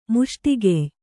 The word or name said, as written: ಮುಷ್ಟಿಗೆಯ್